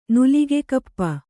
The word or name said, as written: ನುಲಿಗೆಕಪ್ಪ